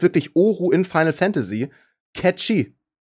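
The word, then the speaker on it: Cait Sith: Spricht man den so aus? – Ja, der wird catchy ausgeführt. – Nein, da steht tatsächlich Sprache catchy.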